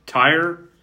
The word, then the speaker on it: Tyr: There's Tyr, T-Y-R. How would you pronounce that? T-Y-R. – Just Tyr? Tyr? – Tyr? Yeah, that sounds about right.